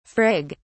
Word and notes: frig: British English

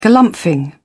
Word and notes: galumphing: British English

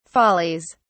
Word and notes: follies: British English

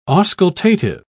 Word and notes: auscultative: British English

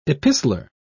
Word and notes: epistler: British English

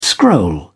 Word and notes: scroll: British English

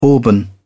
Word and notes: auburn: British English

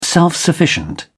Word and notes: self-sufficient: British English

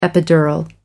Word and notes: epidural: American English

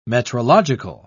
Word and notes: metrological: British English

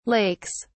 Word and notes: lakes: British English

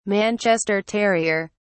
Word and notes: manchester terrier: British English

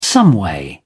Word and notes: someway: British English

